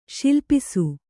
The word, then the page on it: ಸಲ್ಲಪಿಸು: sallapisu